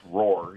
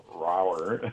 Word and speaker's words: ROIR: So ROIR was a Reach Out International Records, which for some reason they wanted it pronounced Roar, even though it looks like Rower.